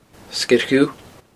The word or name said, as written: Sgeir Dhubh